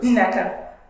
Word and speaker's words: Nekka: Nekka. – Nekka. – Mm-hmm. Okay. So you pronounce the first N and then you say the rest with the short E.